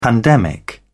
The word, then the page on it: pandemic: British English